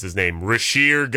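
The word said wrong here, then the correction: Risacher: going to the Mavericks. – So Zachary Risache, who was Risache, the number one pick in one of the worst drafts of all time.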